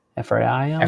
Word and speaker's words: Ephraim: And then when you take a look at the Strongs on Manasseh and Ephraim, how do you say that? – Manasseh and Ephraim.